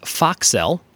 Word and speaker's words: focsle: This is a word pronounced foxel, but it is spelled F-O-C-S-L-E.